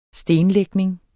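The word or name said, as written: stenlægning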